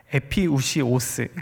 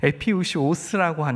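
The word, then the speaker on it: 에피우시오스 (ἐπιούσιος): 이 데일리 브레드는 헬라어로 에피우시오스, 이거 굉장히 발음이 어렵습니다. 네, 에피우시오스라고 하는데 생존을 위한 필요한이라는 의미를 갖고 있습니다.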